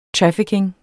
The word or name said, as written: trafficking